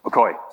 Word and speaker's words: Yokoi: I heard about it last time, and I'm going to butcher the Chinese name, but is it Xiaoqi Yokoi? Are you familiar with this guy? What is it? Okoi, sorry, thank you.